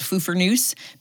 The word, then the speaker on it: Pfeffernuss: We call it Pfeffernuss, but it starts with a P.